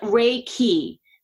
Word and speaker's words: Reiki: And it's pronounced Reiki. So we get a lot of mispronunciation, Reiki, Reiki, but it's Reiki, Rei like the sun, and then Ki is the energy.